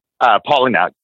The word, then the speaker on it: Palahniuk: And I really enjoy his work, which is Chuck Palahniuk. Is that how you pronounce his last name? Palahniuk? – Palahniuk. Yeah. – Palahniuk.